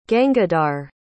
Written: gangadhar

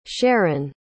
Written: Sherron

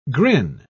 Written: grin

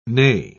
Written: nay

